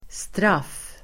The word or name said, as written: straff